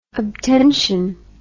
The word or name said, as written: obtention